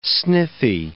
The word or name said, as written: sniffy